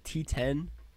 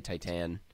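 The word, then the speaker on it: Titane: The programmer at the screening I was at called it T10. Which I was like, that's going to make me sound so douchey anytime I say it, but I guess that's how you pronounce it.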